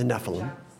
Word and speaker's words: Nephilim: Is that how I should pronounce? The Nephilim. – The Nephilim, yeah.